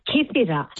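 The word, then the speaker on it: Kythira: How do you say the island again? – You said it right. Kithira.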